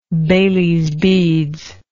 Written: bailys beads

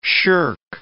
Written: shirk

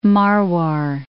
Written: Marwar

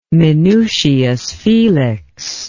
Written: minucius felix

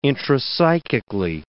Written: intrapsychically

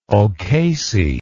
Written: ocasey

